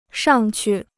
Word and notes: 上去: shàng qù

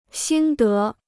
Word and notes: 心得: xīn dé

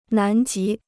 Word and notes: 南极: nán jí

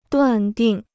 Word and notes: 断定: duàn dìng

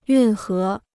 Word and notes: 运河: yùn hé

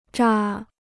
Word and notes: 扎: zā

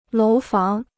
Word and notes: 楼房: lóu fáng

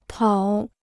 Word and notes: 讨: tǎo

